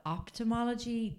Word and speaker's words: ophthalmology: Now, the American Academy of, and this is going to be a hard one, ophthalmology. There we go. – Is it off or opt? – Well, it's spelled off, P-H. But ophthalmology definitely sounds more like optician, which, you know, tracks.